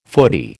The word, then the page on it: foodie: American English